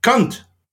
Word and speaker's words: Kant: Now, I should point out this, that if you say Kant in the correct Germanic way, rather than saying Kant, you pronounce it with a softer kind of, so it sounds more like cunt, basically.